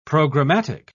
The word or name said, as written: programmatic